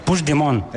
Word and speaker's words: Puigdemont: Me has preguntado antes, ¿cómo se dice este nombre? – Sí, lo voy a intentar. – Puigdemont. – Puigdemont. – Vale. – Puigdemont. – Eso es.